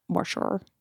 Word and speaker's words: Morshower: Glenn Morshower. – Morshower. Yeah. – I think. Morshower. But it's spelled M-O-R shower. So it's a little weird.